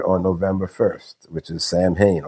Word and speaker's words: Samhain: Halloween's origins date back to the ancient Celtic festival of Samhain, and that's pronounced Samhain.